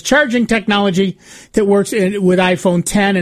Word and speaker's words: iPhone X: The iPhone X, pronounced iPhone 10, is Apple's $1,000 anniversary device with an edge-to-edge OLED display, facial recognition, and no home button.